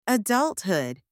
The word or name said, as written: adulthood